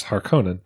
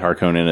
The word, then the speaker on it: Harkonnen: House Harkonnen. – I said Harkonnen as well, yeah. – So we're following... That's what I say too. – Okay. – The other way you might say is like Harkonnen.